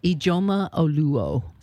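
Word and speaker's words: Ijeoma Oluo: Ijoma Oluo. – Spell it, please. – Ijeoma. – Okay. – Last name is Oluo. – Thank you. – Oluo.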